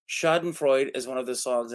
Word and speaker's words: Schadenfreude: Schadenfreude. – Schadenfreude. Okay. Yes. Schadenfreude. Okay. – Schadenfreude. That's how it's pronounced.